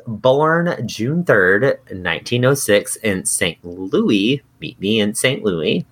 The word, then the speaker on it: St. Louis: And overwhelmingly everyone was like, it's St. Louis. You pronounce the S.